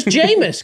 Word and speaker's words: Jameis: Famous. Amos rhymes with famous Jameis.